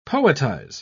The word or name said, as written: poetize